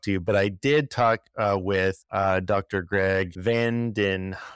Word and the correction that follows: Vanderheiden: Am I saying that right? Vanderheiden. Okay, I'm going to say that again.